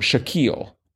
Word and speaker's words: Shaquille: Is pronounced to rhyme with O'Neal, so it's Sha, like the beginning of the word sush, and keel, like the keel of a boat. Shaquille.